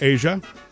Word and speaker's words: Aja: And she says, it's pronounced Asia. That's beautiful. And it's spelled A-J-A.